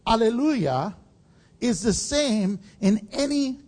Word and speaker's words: alleluia: First of all, some of your translations may say hallelujah, but the actual pronunciation for us is alleluia.